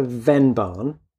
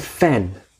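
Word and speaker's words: Vennbahn: So we have reached the, and I called it the Venban, because that's how it's spelt, but it's the Fen. It's actually an F. Mm-hmm. It's like the Fenlands, the Fenway.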